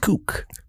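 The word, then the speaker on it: Cooke: It's actually pronounced Cook.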